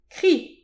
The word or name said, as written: kriz